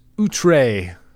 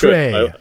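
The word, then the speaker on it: outré: That's outre. – Outre. – You have to pronounce the accent that I always have to look up how to type.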